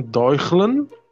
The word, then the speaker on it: Deutschland: Tá Temos também o primeiro voo comercial do Zeppelin Deutschland. Pronunciei certo, o mago das línguas. E ele tem o T. Oi. Deutschland. – Não, não, não. É, tem o T, Deutsch.